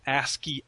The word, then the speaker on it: ASCII: I'm assuming you might know this, what ASC2 is. – Yeah, that's the... when you're using little X's and zeros and ones and whatever to make an image. It's pronounced ASCII art.